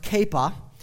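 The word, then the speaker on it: Kepha: Cephas in the Aramaic would have been pronounced Kappa.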